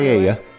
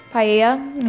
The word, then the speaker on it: paella: Paella. – Paella, yeah. See, that should have been easy to say, but... – Paella.